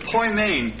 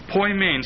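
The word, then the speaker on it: poimen (ποιμήν): And the word is poimen, P-O-I-M, long E-N, poimen.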